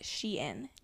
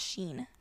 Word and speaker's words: Shein: And also it's pronounced Shein. That is not Sheen.